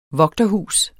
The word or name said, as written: vogterhus